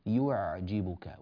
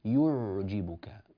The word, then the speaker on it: يعجبك: هنا في كلمة يعجبك لا ينبغي أن تضم الشفتان في أثناء قراءة هذه العين، يعني احنا الآن ضممنا الشفتين عندما نطقنا حرف الياء من يو يو، لاحظوا الشفتين يو. وأما العين فعين ساكنة، فنعود بالشفتين إلى الخلف قليلا فنقول يعجبك ولا نقول يعجبك.